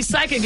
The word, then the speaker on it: psychic: So you're right, because when we see psychic, it's spelled P-S-Y, right? – I couldn't tell you. – Yes, yeah. This, yep. – So the P is silent.